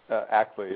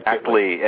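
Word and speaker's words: Act.ly: And is it ACT-LEE? Is that how you say it? How do you say the name of your petition site? – ACT-LEE, yeah. Uh-huh. – Like ACT-LEE.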